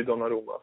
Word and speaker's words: Donnarumma: Donnarumma, jag, nu kanske jag uttalar det fel. Du kanske har ett bättre, finare uttal? Nej, nej, det, det är helt rätt. Gianluigi Donnarumma. Så.